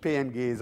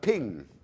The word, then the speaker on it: PNG: Und da erkennen Sie auch die Leute, wie spricht man PNG? Nicht PNG, sondern man sagt Ping.